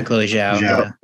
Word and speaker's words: Chloe Zhao: I appreciate that Chloe Zhao. Sorry, is that how you say your name? – Yeah, Chloe Zhao.